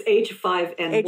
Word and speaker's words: H5N1: Am I saying that right? H1N5? – It's H5N1. – H5N1, thank you.